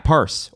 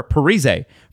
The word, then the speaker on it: Parise: And they used to say his name wrong in the video games. Zach Parise, or Parise for the rest of us.